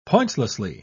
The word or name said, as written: pointlessly